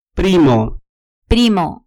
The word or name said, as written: primo